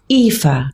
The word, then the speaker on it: Aoife: But the names, some of the people's names, I was talking to someone whose daughter's name is Aoife, and it's spelled A-O-I-F-E. – Yeah, Celtic. Yeah, yeah. In the Irish. – It's like Eve. Yeah. It's like the name Eve. It's Aoife.